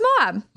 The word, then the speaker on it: Moab: I hope we're saying that right, Moab. – No, it's Moab. – All right, cool. – Yeah, it's Moab, Utah.